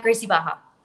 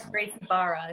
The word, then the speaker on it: Gracie Barra: At Gracie Baja. – Oh, okay. Nice. – Or Gracie Barra, if you want to pronounce the R's. Some people do.